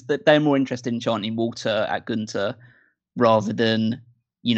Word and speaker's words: Walter: and the crowd kind of ruined it because they keep shouting Walter, which is the correct way to pronounce his name.